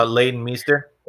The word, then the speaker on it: Leighton Meester: Lisa Mister. I can't say her name. – Oh, Leighton Meester.